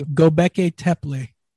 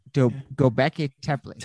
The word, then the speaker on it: Göbekli Tepe: Like it's, and the other part, Göbekli Tepe, I think is how you say it in Machu Picchu. – Göbekli Tepe. – Göbekli Tepe. There we go.